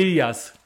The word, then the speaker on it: Ilias: Omers äh, Ilias. Genau, so ist es richtig, Ilias, genau.